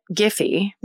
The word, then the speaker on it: Giffe: And 35-year-old ex-biology professor turned real estate agent George Giffey Jr. So the spelling of this is G-I-F-F-E. Hmm. So I was reading it, pronouncing it Giffey. – Yeah. – Could be GIF. – Then why would they put the E at the end? I think you're right with Giffey.